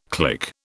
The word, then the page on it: click: British English